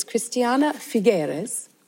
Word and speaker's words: Cristiana Figueres: So this is Cristiana Figueres.